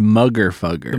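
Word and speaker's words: Moogerfooger: The Mooger Fugger 103, the 12-stage phaser. And those are buku dollars. – I believe it's pronounced Mugger Fugger.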